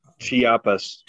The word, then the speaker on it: Chiapas: He's actually the guy who wrote Homage to the Chipas, I believe. Is that the correct pronunciation? – Chiapas. – Yeah, Homage to the Chiapas.